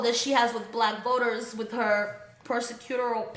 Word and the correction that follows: prosecutorial: past, mm-hmm. if I said that correctly. Yeah. – Prosecutorial. – Damn, I've had a lot of wine. prosecutorial